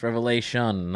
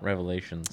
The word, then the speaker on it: Revelation: said it right, folks. It's Revelation, not Revelations. – Revelations. – There's only one Revelation.